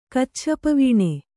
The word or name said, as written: ಕಚ್ಛಪವೀಣೆ